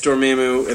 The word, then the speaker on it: Dormammu: I have never heard it pronounced, and I was not imagining it pronounced that way. – It's Dormammu, and then he's Rakanu.